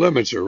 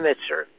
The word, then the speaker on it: Lemnitzer: General Lemnitzer, right. – Lemnitzer.